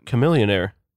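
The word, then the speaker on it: Chamillionaire: Yeah, Chameleonaire. – What because do you think his name was a I'm pretty sure he rhymes about blending in with his money. – The H is silent.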